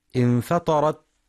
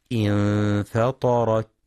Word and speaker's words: انفطرت: وينتبه القارئ للتاء المفتوحة، فلا يقرأ بترك الهمس انفطرت انتثرت، يقرأ خطأ انفطرت انتثرت، أو ينطق الهمس مشوبا بالسين نحو انفطرت انتثرت فجرت. وإنما اللفظ الصحيح انفطرت انتثرت فجرت.